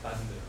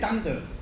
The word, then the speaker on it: thunder: 不讲，有说什么苦啊、随时会来，你都不知道、啊，叫晴天霹雳，是这样子，Night and the Thunder，闪电跟打雷啊，Night and the Thunder，你们怎么呢？Thunder，Lightning and Thunder。 – 哎呀，你怎么念那个音呢？那都念的当得，那么当得的啦、啊。哎呀，差有够多。你们真的念当得？